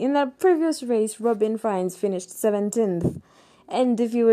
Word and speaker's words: Frijns: I used to call him Frigens because it's spelled as F R I J N S, but it's Robin Frijns.